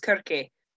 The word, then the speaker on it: Circe: Circe, or her original name pronunciation was Circe, which I told Mark I wasn't going to be that person, but I am that person.